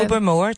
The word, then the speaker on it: Tobermory: I probably mispronounced it. Tobermory. Is that how you say it? – Tobermore. Tobermore is right.